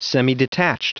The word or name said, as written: semidetached